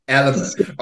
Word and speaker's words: Elemis: Elemis. I say Elemis because that's how it's spelled.